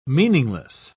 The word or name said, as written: meaningless